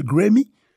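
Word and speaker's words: Graeme: Alors, tipologi ekri T-Y-P-O-L-O-G-I-E. E. Ok? Bon. Mais, en définition de euh, Grammy, alors, m'a raconte si m'bien prononcer, non, monsieur, l'ekri G-R-A-E-M-E. E. Bon, prononcer Grammy, Goldsworthy.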